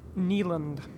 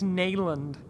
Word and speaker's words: Neyland: Neyland. Yes, you are. – I could have sworn it. – I think it's Neyland.